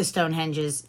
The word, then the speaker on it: Stonehenge: How do you say Stonehenge.